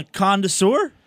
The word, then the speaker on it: connoisseur: It's Casual and the Connoisseur? – There's no D in it, just connoisseur.